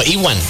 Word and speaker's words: Ewan: No, o sea, pregunto, porque según yo era Ian, ¿no? Ian McGregor. – No, Ewan. – Ewan McGregor. – ¿Es Ewan? – Ewan McGregor. – ¿Así se pronuncia? – Ajá. – Okay. – O sea, E-W-I-N. – Ajá. Ewan McGregor. No es Ian.